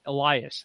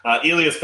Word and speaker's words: Elias: And the way that they pronounce his name over there was Elias Pettersson. So I'm saying that when he gets over here, and everyone's like, what the hell is wrong with you? It's Elias. So I still like Pettersson.